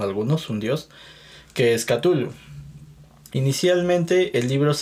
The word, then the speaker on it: Cthulhu: Pero bueno, respecto a este libro, el, La llamada de Cthulhu, yo lo pronuncio así, Cthulhu. Eso es algo que me han enseñado. Pero bueno, como ya lo mencionó Marilu, no hay corrección,